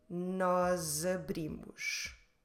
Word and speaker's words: nós abrimos: So instead of split the two words, nós abrimos, let's run them together and let's pronounce the S in the end of nós as if it was a Z. Nós abrimos.